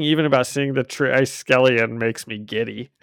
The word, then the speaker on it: Triskelion: And we open on the Triskelion. – I think it's Triskelion. – Yeah, I know this. I do love seeing it. I wish I gave it a better pronunciation there to start,